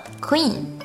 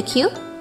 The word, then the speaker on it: queue: 这个单词长得跟 queen。王后很像，因此读音也容易混淆，容易读成了 queen，但实际上它的发音应该是 q q，记住和字母 q 的读法一样。 How long were you in the queue？